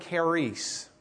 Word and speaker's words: charis: There's a Greek word charis, C-H-A-R-I-S, charis. If you do the C-H as a ch, like we would pronounce it, C-H-A-R-I, instead of S, what word do you come up with? C-H-A, charity.